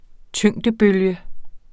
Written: tyngdebølge

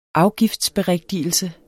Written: afgiftsberigtigelse

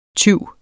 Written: TYVS

